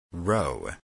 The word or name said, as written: row